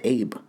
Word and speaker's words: Abe: It's spelled A-B-E, and a lot of Americans pronounce it Abe, but it is Abe.